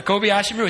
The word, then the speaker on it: Kobayashi Maru: And they put together what a Trekkie would call, see if I can pronounce this right, a Kobayashi Maru. Did I get that right? You got Trekkie? A Kobayashi Maru.